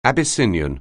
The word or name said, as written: Abyssinian